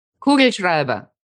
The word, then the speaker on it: Kugelschreiber: My favorite word in German, and I hope I say it right, Kugelschreiber. – Kugelschreiber.